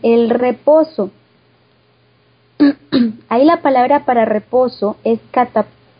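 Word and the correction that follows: catapausis (κατάπαυσις): A ver, déjeme la leo, porque catapausis, lleva tilde en la segunda, catapausis.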